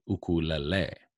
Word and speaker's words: ukulele: And I always say, if you want to try and pronounce it and sound traditional, flare your nose. If you flare your nose and say ukulele, it sounds very traditional.